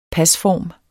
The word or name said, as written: pasform